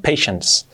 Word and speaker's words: patience: Parece que es una T, pero no, patience. Es la N y la S ahí, patience, ¿vale? Patience, patience, acabado en S. Ya sé, paciencia, no aparece ninguna S allí, pero la pronunciación es patience.